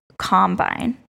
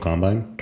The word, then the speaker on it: Combine: This week is the NFL Combine. Is that how you say it? Yeah, NFL it? Combine. – Combine. It's spelled like combine, but you say combine.